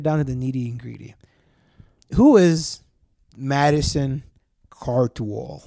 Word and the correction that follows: Cawthorn: Did I say that right? Who is this guy? – Madison Cawthorn. – Cawthorn, is that how you say it? – Yeah. Yeah, Madison Cawthorn.